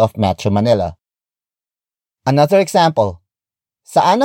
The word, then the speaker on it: saan: Word for word, it's saan ang pinakamalapit na bar.